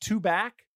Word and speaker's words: Tubac: I think it's pronounced Tubac. That's how they say it down there, but it's T-U-B-A-C.